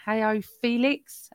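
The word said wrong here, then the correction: João Félix: How'd you say it? – Joao Felix.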